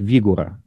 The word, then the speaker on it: Вигура: И у него фамилия Вигура, но все произносят это как раз как фигура. – Да, ну, фамилия произносится так, Вигура, да? – Вигура.